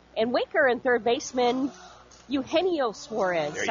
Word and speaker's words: Eugenio Suarez: But how do you say his name? – Eugenio Suarez.